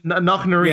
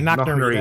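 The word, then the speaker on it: Knocknarea: Knocknari? I can't pronounce it. – Yeah, Knocknari. – Yeah, Knocknari. That's it.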